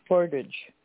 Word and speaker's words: Portage: Portage. – I'm sorry? – Portage. Portage. – How do you spell it? How do you spell it? – P-O-R-T-A-G-E.